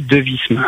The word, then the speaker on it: Devismes: Jérémy Devisme. Alors, Devisme, c'est D-E-V-I-S-M-E-S.